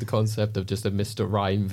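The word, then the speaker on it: Mr. Rime: Mr. Rhyme. DC or not DC? – This is because Mr. Rhyme rhymes with Mr. Mime.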